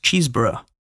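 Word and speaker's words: Chesebrough: According to Unilever, Robert, I believe it's pronounced Cheeseborough, a chemist from New York, invented petroleum jelly after a visit to the oil fields of Titusville, Pennsylvania in 1859.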